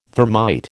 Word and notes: thermite: American English

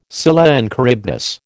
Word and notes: Scylla and Charybdis: American English

